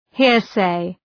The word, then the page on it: hearsay: ‘hıərseı